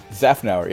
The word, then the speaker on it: Szafnauer: Aston Martin has announced that Formula One team principal Otmar Szafnauer, did I pronounce that correctly? – Szafnauer, yeah.